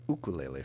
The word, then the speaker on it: ukulele: immediately following the events of the Wine Country Ukulele Festival, or we say Ukulele Festival, don't we? If we were doing the proper Hawaiian pronunciation.